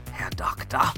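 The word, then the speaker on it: Herr Doktor: You have to kind of say it like Ronald Lacey and Raiders of the Lost Ark. Herr Doctor.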